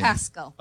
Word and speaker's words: Pascal: Pascal. – Okay, Dr. Pascal? – Pascal, like Pascal from Hot Springs, Arkansas.